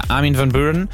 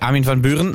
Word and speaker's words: Armin van Buuren: Ja, er ist die Trance-Legende, Armin van Buuren. Buuren, jetzt habe ich schon wieder falsch gesagt, Armin van Buuren.